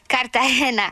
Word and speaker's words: Cartagena: Cartagena.